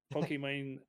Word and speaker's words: Pokimane: Pokimane, very popular Twitch streamer.